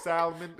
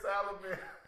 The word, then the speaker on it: salmon: Like, I can't even make myself pronounce the L in salmon. Salmon? – Salmon. – That's what he said. He said salmon. He put, like, an L-U in there. He said salad. He said, yeah, the cucumber bites with the salmon. – Not only did you pronounce the L, you added a whole syllable.